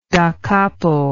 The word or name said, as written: dacapo